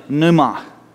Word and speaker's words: pneuma: The spirit comes from a Greek word, and that word is called pneuma. It's pronounced pneuma.